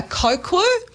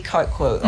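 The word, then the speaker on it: coquí: It's called I can never pronounce it right, a cocu, spelled C-O-Q-U-I, and it is just, yeah, this common bloomin' frog everywhere.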